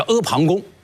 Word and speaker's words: 阿房宫: 就是在一个大山的旁边的一个宫殿啊，叫阿房宫。那这个有没有资料作为佐证呢？有。所以秦始皇本纪里面就专门记载了，说这个阿房宫啊，坐宫阿房，故天下谓之阿房宫。